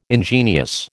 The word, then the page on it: ingenious: American English